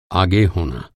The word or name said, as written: होना